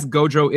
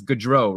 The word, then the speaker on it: Gaudreau: By the way, I remember that I read that someone complained that I say his name incorrectly. I think it was on Reddit. So I guess Gaudreau isn't how you say it. It's Gaudreau, right? – Yeah, let's go with that.